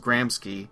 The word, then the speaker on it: Gramsci: The band's name is Gramsci. sometimes pronounced Gramsci, spelled G-R-A-M-S-C-I, melodic.